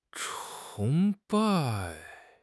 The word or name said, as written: 崇拜